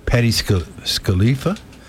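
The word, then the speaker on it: Patti Scialfa: And Mrs. Bruce Springsteen, Patty I can never say her name. Patty Scalifa is 69. – You just said her name perfectly right there.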